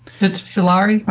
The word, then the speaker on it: fritillary: Common snake's head fritalia. Something like that. – Fritillary. – Fritillary? Okay. – It's spelled FRITILLARY.